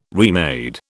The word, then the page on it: remade: British English